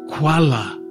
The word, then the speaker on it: Kuala: "Kuala," she says. "Two syllables, not like the bear."